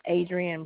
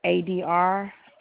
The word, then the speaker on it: Adrienne: It's Adrian Pringle. – Can you spell that for me? – Adrienne. Last name Pringle, like the potato chip. – I'm sorry, you said A-D-R... – I-E-N-N-E.